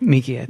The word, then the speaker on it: Miike: Miike? Am I saying that right? – Miike, I think.